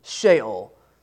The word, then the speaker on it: Sheol: Sheol or Sheol. I don't know how to say it. Mason? Yeah. Let's go with Sheol.